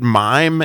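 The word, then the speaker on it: mime (מים): So just to give us a highlight of more Mem, since we're in the Mem section, the word mime is how you would pronounce water in Hebrew, and it starts out with an open mem, because there's two different kinds of mems.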